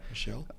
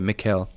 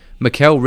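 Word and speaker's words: Micheál: And then, I don't know, it's not Michael. It's spelled out Michael, but you say it different. I think it's European or something like that. – Michelle? – No. Mikkel? I think that's how you say it.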